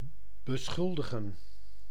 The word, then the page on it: dénoncer: France; [de.nɔ̃.se]